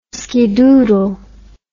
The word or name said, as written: skidoodle